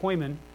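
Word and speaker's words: poimen (ποιμήν): Okay, that's how you say it, poimen. But it looks more like poor man.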